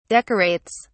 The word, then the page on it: decorates: British English